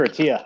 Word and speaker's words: Attia: ATTIA. – And Peter Attia, I think. – Attia. And thank you for correcting me on my bad pronunciation.